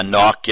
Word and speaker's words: Nauck: but Todd Nuck, N-A-U-C-K? – Yeah, Nuck, yeah, Todd Nuck or Nuck, yeah.